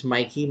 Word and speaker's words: Mikey: My name is Mikey Medina on Facebook. It's spelled M-I-K-E-Y.